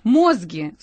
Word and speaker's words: мозги: Мозги, с ударением на «о», этого киллера, это все очень эмоционально окрашено, мозги этого киллера были найдены в гараже.